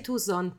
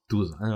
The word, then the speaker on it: Tucson: Perché Tucson. Se tu mi dici una città americana, io so dov'è. – Sì, sì. – Ma si dice Tucson, però. – Tucson. Oh. – Si scrive Tucson. – Sì.